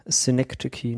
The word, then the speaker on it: Synecdoche: Synecdoche New York.